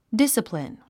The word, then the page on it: discipline: dísəplin; ディサプリン